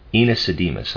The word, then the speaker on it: Aenesidemus: In Göttingen, Schopenhauer heard the teachings of Gottlob Ernst Schulze, the author of the book titled Enesidemus, I'll spell it A-E-N-E-S-I-D-E-M-U-S,